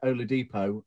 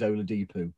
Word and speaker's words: Oladipo: So instead of being Oladipo, it's always Oladipo.